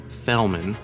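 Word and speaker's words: Fjellman: The wonderful sociological treatise on Walt Disney World, it's a book called Vinyl Leaves by Stephen M. Fellman, which there's a silent J in there, discusses the World Key kiosk.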